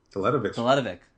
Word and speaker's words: Teletovic: I like Mirza Teletovic. – Teletovic.